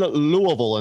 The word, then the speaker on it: Louisville: Up first, speaking of the controversy involving the Kentucky Derby, from right in Louisville, and that is how you say it,